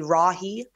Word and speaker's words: Rahe: But it's Anna, A-N-N-A-R-A-H-E. It's pronounced Ray, like ray of sunshine, but it's more phonically Rahe.